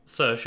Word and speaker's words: Saoirse: Because it's Saoirse Ronan. She's Irish, is she? – Saoirse, yeah.